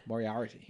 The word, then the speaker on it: Moriarty: Moriarty? – Moriarty. You nailed it the first time. – I feel in my head that I didn't. – Yeah. – Moriarty. More hours for tea.